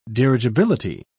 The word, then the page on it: dirigibility: British English